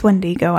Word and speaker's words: Wendigo: It's Wendigo.